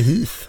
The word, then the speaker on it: Heath: Karl Heath, Uttalar du ditt namn så? – Ja, det kan man göra. – Ja. Det går bra. Hur säger du då? Jag säger Heath. Heath! – jag är ju engelsman.